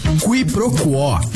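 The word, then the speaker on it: quiproquó: Qui pro quo. Q-U-I-P-R-O-Q-U-O com acento agudo. – Qui pro quo, também informalmente pronunciada como qui pro quo.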